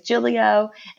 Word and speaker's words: Giglio: So my last name is Giglio, and it doesn't sound or look like that. When you see how it's spelled, looks like Giglio or something.